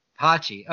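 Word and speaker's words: Poche: They still have Colin Posch. I don't know if I'm saying that right. – Pochi. – Okay, they still have Colin Pochi, who they gave save chances to with Nick Anderson on the squad last year.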